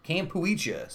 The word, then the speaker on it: Kampuchea: Cambodia used to be something called Kampuchea? Kampuchea.